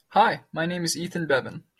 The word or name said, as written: ethan